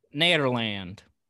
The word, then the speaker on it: Nederland: I think it's pronounced Nederland. – I think it's Netherlands.